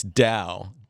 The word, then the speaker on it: Tao: It's Tao. Taoism is the ancient sort of Chinese philosophy. It's not a religion. It's a philosophy. I have many times read their basic text, which is the Tao Te Ching.